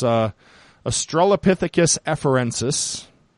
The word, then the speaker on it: Australopithecus afarensis: Lucy, who we will be introduced to Lucy here soon, but it's Australopithecus afarensis.